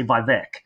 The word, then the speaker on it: Vivek: I should say Vivek. Vivek, because that's how you pronounce it.